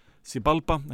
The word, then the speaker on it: Xibalba: mig langan tíma að reyna að lesa þetta nafn Sibalba, það er X-I-B-A-L-B-A Sibalba, jú en ég fór einmitt á netið og valdi How to Pronounce og skrifa þetta nafn það er víkt Sibalba en þetta er ágættisrokkir eh,